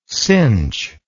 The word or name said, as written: sinh